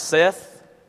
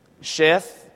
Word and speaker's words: Seth: It can be pronounced Seth, Sheth.